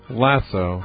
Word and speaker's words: lasso: Lassos? Lassos? How do you say that word? You're from out west. You must know. – Lasso. – Lasso? Like Lassie. Lasso.